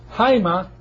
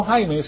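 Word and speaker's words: Jaima (αἷμα): Jaima, no Jaime, sino Jaima, es sangre. Jaima. Así se pronuncia en el griego, porque tiene esta respiración fuerte.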